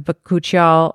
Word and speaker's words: bakuchiol: And so the other question I had is, you were talking about, is it bakuchiol? How do you say it? – Bakuchiol. Yeah, there you go.